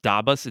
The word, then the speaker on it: DABUS: I think it's pronounced Dabus,